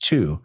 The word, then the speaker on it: Thy: The area is called Two. It's spelled T-H-Y, but pronounced Two.